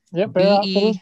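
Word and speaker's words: Bexar Outfitters: You're still a famous YouTuber on, it's Bear Outfitters. Did I pronounce it right? – Yep. Bear Outfitters, B-E-X-A-R. – B-E-X-A-R Outfitters.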